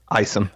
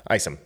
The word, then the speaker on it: Isom: Isom is that how you say it? Isom. Isom. That's right.